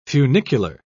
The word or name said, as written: funicular